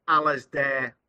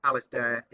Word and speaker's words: Alasdair: On Twitter, I'm Alasdair underscore Lord. That's my name. So Alasdair is spelt Alasdair, and Lord, Lord.